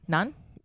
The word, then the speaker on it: Nantes: You know, I don't know if I'm pronouncing this right, but it's Nantes, France. I discovered Nantes. – Him. Nantes? – Yeah, Nantes. – Nantes. Nice.